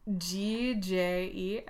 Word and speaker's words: Gjenganger: I think it really is Gjenganger. – Gjenganger. – It is Gjenganger.